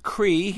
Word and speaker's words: Creigh: C-R-E-I-G-H, pronounced Cree.